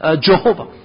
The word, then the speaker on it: Jehovah: So instead, even though they are using the same consonant, but they are putting a different vowel pointings, as a result, the pronunciation becomes Jehovah.